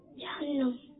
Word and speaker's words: جہنم: کچھ لوگ کہتے ہیں جہنم اور کچھ لوگ کہتے ہیں جہنم. صحیح الفاظ کون سا ہے؟ – جہنم.